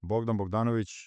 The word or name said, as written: Bogdan Bogdanovic